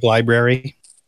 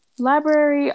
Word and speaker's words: library: Like library. – Library, all of those are pronounced though. – Well, some people don't. They say library. If you listen, well, they're incorrect. They are grossly incorrect.